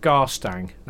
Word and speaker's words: Garstang: Tell us about Garstang. Now, have I pronounced that correctly? – You have. Very easy. Very fine.